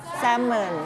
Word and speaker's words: salmon: salmon นะคะภาษาไทยใช้คำว่าปลา salmon แต่ภาษาอังกฤษไม่ใช่ salmon นะคะ